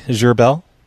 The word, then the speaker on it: Zerbel: It's Roy Gerbel. – Z-E-R-B-E-L? – Yep, that's it.